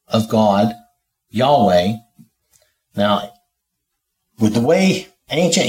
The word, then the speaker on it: Yahweh: We pronounce it Yahweh,